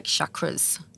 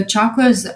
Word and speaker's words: chakra: So chakras, how it's spelled is C-H-A-K-R-A-S. And in the West, we say chakras, but it's actually chakra. That's how we pronounce it, chakra, originally.